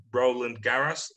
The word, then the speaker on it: Roland Garros: And she, yeah, she's a big star in Poland right now because she's like the first tennis player to win the Roland Garros.